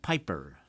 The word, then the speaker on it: Pijper: We turn next to music by the 20th century Dutch composer Willem Pijper. That's spelled P-I-J-P-E-R.